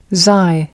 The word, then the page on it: xi: American English